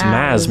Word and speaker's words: Mads: I'm sorry to inform you, but you pronounce Mads Mikkelsen wrong. The D in Mads is silent. It's Mads Mikkelsen. – Mads Mikkelsen.